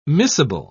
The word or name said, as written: miscible